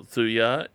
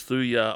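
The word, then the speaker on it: Thuja: I would recommend a trial on Thujar.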